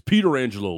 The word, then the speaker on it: Pietrangelo: "You guys are saying Petrangelo's name wrong. It's Peter Angelo, like Frank Peter Angelo won the cup with the Penguins years ago."